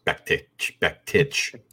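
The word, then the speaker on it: Bektić: Bektik versus Gregory. I'm taking Bektik. – Mirsad Bektik. – Even though I believe they it's actually pronounced Bektich, as if there's a CH on there for whatever reason.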